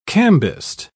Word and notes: cambist: British English

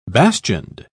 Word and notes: bastioned: British English

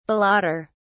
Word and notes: balloter: British English

